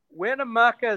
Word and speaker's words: Winnemucca: Winnemucca is the next.